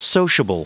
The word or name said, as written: sociable